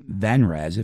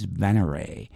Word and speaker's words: Venray: And this past week, we talked to Ven from Venray, that I kept pronouncing Venrez. If you listened to that interview, you heard me mispronounce that name, as well as when I played a track from their record last week, too. But it's Venray.